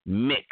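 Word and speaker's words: Mx: So it's M-X, and it's pronounced mix, M-I-X, mixed.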